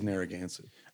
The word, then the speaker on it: Narragansett: I purposely go there and order Narragansett and say it wrong and just have him correct me every fucking time. – No, it is Narragansett.